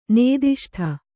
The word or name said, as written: ನಾದಿಷ್ಟ